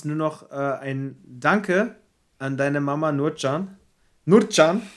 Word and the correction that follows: Nurcan: ist das richtig ausgesprochen? Fuck. Ich scheue mich immer davor. Wie lange kennst du meine Mutter und du kriegst es immer noch nicht geschissen, ihren Namen richtig auszusprechen? – Nurjan. Nurjan, ganz liebe okay, Grüße ja.